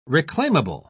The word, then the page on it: reclaimable: British English